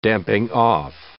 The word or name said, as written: damping-off